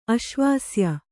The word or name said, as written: ಅಶ್ವಾಸ್ಯ